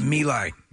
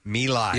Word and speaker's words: My Lai: How's it pronounced? – My Lai. – My Lai.